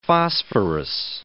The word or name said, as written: phosphorus